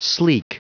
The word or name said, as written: sleek